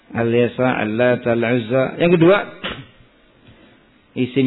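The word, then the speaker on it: al-yasa (اليسع): Jadi yang benar dibacanya il-yasa atau al-yasa. Il-yasa atau al-yasa. Al-yasa kan? Sebagian il-yasa. Ada al-yasa. Al-yasa.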